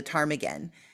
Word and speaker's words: ptarmigan: So I use the bird, the ptarmigan, because the P is silent.